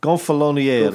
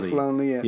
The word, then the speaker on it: Gonfaloniere: How do you say Gofaloniere? How do we say that? The leader of the city. Gonf, Gonfaloniere. – Gonfaloniere. Yeah. yeah.